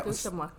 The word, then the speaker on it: Nitotemak: Nitotemak, is that how you say it? – Yeah. – Nitotemak.